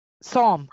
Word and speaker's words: Psalm: Her kids and Zalm. Beyonce's. – Zalm. – Psalm. – How do you pronounce it? – Psalm. Psalm. – But it has a P. Psalm.